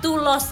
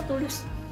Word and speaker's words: Tulus: Jadi Tulos, harusnya kan Tulos kan? Tulos kan kalau Inggris. Ternyata Tulus. – Tulus. Tulus. Ya, bacanya Tulus.